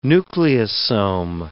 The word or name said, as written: nucleosome